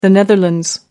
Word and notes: netherlands: American English

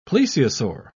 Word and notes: plesiosaur: British English

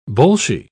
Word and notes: bolshie: British English

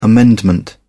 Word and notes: amendment: British English

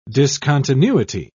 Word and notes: discontinuity: British English

